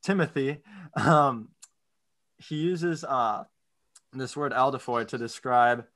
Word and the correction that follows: adelphoi: I think it's adelphoi. – Adelphoi? Yeah. My bad.